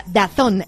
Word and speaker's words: DAZN: DAZN, pero ya sabes que se pronuncia Dazón.